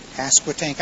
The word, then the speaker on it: Pasquotank: Pasquotank.